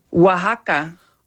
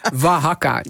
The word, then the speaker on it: Oaxaca: Hoe moet je het nou uitspreken? – Oaxaca. – Oh, dat is heel wat anders. Oaxaca. Dank je wel. Ik zal het nooit meer fout doen.